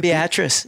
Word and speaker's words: Beatrice: Then we came to Beatrice. How do you say it? – Beatrice. – Beatrice.